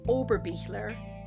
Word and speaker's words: Oberbichler: I would love to connect with you on social as well by typing in Lisa Oberbichler.